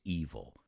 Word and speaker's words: evil: The devil is evil. Even though the spelling is similar, I should say the same, except for the D, they're not pronounced the same.